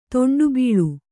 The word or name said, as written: ತೊಂಡುಬೀಳು